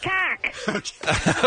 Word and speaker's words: macaque: macaque.